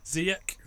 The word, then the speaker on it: Ziyech: Ziyech, is that how you say his name? – Yeah.